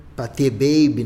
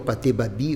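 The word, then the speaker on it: Pathé Baby: Patê Baby, né? Ou Patê Babi, como, como quiser pronunciar, é, que funciona.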